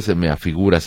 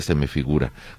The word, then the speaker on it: se me figura: Hace unos minutos dijiste se me afigura, se pronuncia se me figura.